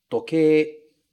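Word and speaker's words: tokei: A gente tem toque, toque. Uma coisa interessante no toque é que a gente escreve toquei, mas a gente fala toque, toque, toque. Sempre em japonês, esse ei vai ser pronunciado como e.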